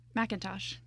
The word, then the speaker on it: McIntosh: McIntosh. – Oh, it is McIntosh. There's a C in there, and it kind of threw me off.